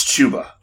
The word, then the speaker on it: Chuba: Is it Chuba or is it Chuba? I'm not even sure how to say it. – I believe it's Chuba.